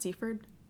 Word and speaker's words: Seaford: Did I say that right? Seaford? – Yeah.